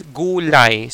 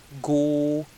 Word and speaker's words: gulay: So vegetable, the word for vegetable that is also used in Tagalog is gulay. So that's the word, Cebuano word for uh, vegetable that is used also in Tagalog. So gulay.